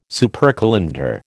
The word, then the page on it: supercalender: American English